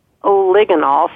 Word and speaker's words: oligonol: There's a really interesting nutrient, which is called oligonol. So it's spelled O-L-I-G-O-N-O-L, so oligonol.